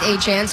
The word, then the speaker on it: Etienne: It's spelled Etienne, but he says it's now pronounced Etienne, so we're going to go with that.